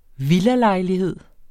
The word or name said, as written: villalejlighed